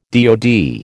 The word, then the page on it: DOD: American English